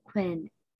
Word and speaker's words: Quynh: So I will make it a little bit sounds like Quynh, spelled as Q-U-I-N-N. And then they will ask me, is it actually Quynh or do you want to say like my original name from Vietnam? It's like Quynh. So I would try to teach them how to say my name in Vietnam.